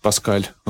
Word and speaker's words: Пасхалий: В 1105 году Боемунд прибыл в Рим, где его принял папа Пасхалий, или Паскаль в западном произношении.